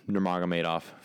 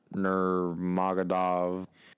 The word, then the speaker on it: Nurmagomedov: However, as far as the people that are in this room right now, that being just me, I'm the biggest Khabib Nurmagomedov fan. And notice how I pronounced that correctly. Not Nurmagomedov.